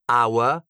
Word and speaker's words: hour: Una cosa interessante è quanto è importante l'H prima di tutto, perché se una parola inglese c'ha l'H all'inizio, ci sono delle eccezioni dove c'è scritto l'H ma non si pronuncia, ma sono tipo tre. C'è honor, hour, che sarebbe ora, e poi c'è... Robert, what's the other one? There are three, right? Three words in English. – Honest. – Honest, honest. Ecco, onesto.